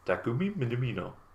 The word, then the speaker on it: Takumi Minamino: I actually googled how do you pronounce Minamino when they signed them. And it's just a like thing comes up on Google. Takumi Minamino.